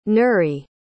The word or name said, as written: nuri